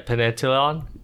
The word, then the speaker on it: pentathlon: Pentathlon, like triathlon and marathon. Pentathlon. – Can you spell it for me, please? – P-N. – P-N? – P-N. – P-E-N? – Yes. Talon. T-A-T-H-L-O-N. – Oh, pentathlon? – Yeah, pentathlon. Modern pentathlon.